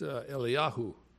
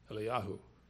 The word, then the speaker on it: Eliyahu: And in Hebrew, Elijah is pronounced Eliyahu, Eliyahu.